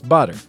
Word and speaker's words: Botter: So it's Botter. He said Botter. It rhymes with water.